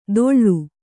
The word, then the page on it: ತೊಳ್ಳು: Kannada